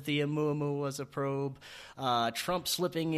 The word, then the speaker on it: Oumuamua: In the past few years, we've had the I always mispronounce that thing. Oumuamua. There it is. The supposed alien space. – I have trouble with it too. I don't know anybody who doesn't. – Yes, Oumuamua.